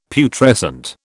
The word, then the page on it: Putrescent: British English